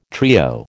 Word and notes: trio: American English

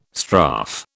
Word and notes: strafe: British English

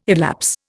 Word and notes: elapse: Indian

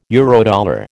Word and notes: Eurodollar: American English

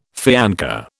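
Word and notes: fiancé: British English

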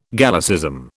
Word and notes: Gallicism: British English

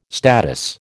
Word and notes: statice: American English

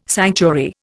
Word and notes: sanctuary: Indian

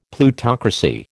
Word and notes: Plutocracy: American English

